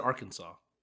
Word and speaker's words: Arkansas: The S is silent, by the way. – I said Arkansas.